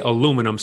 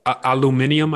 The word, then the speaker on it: aluminium: I say aluminum, so we pronounce it a little differently. But when you look at the aluminum market, sorry, I It would be hard for me to say it the way you say it. Aluminium, I believe you said, but I can do aluminium or aluminum.